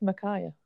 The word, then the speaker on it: Micaiah: Micaiah? Is that how you say it? – I think it's Micaiah.